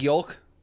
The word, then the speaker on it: yolk: So like, I guess an egg yolk is pronounced. – Oh, I saw this. – It's pronounced yolk like folk, not yolk like joke.